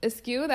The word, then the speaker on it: eschew: Eskew, that's how you say it? – Mm-hmm. – All right. Okay, eskew. – Let us look. – It looks like eschew. E-S-C-H-E-W.